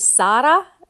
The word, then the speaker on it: Sara: is Sara, which is actually the way in Hebrew how Sarah is pronounced.